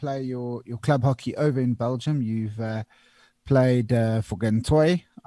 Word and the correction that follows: Gantoise: I don't know how to pronounce it, actually. So you can please correct that. And that. – Yeah, Gantoise. – Gantoise.